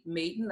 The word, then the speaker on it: Maiden: On Facebook, I am Basheba, that's spelled B-A-S-H-E-B-A, Maiden, like your maiden name, M-A-I-D-E-N.